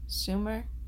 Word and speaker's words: Schumer: Schumer? – Yeah, that's how you say her name.